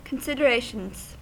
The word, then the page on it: considerations: American English